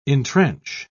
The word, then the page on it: intrench: British English